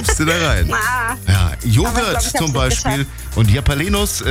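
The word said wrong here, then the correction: Jalapeños: wie wird das immer richtig ausgesprochen? – Jalapenos.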